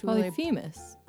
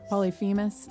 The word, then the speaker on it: Polyphemus: Polyphemus. – Polyphemus.